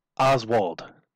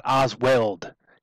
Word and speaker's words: Oswald: Just as a quick sidebar, I hate that Canadians cannot pronounce Oswald. It's not Oz Weld. He's not welding two pieces of metal together. It's Lee Harvey frickin' Oswald, not Oswald.